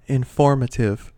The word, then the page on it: informative: American English